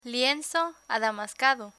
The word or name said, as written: lienzo adamascado